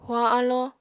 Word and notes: huañ alló: apatani

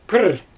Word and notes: kirr: Xyzyl

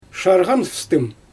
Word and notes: ʃarɣanfstɯm: Xyzyl

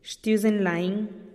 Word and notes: xtiuzen lain: TdVZ

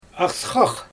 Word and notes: asxax: Xyzyl

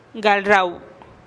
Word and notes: gal rau: TdVZ

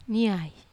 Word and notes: nai: Wamesa

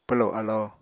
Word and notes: pwlo alo: Apatani

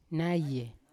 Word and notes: naye: Yawa

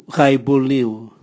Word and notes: gaibulniu: Raga

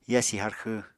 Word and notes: yasi harxw: apatani

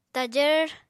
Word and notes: tajvr: apatani

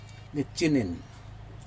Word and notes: nitjinin: Aneityum

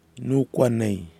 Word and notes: noukuanei: kwamera

